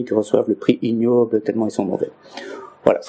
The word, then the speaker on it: Ig Nobel: En anglais, ça s'appelle, on prononce ça ignoble.